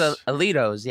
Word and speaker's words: Alioto's: A-L-I-O-T-O-S. Alitos. Alitos. – Alito. Alitos. – It's Alitos, yeah.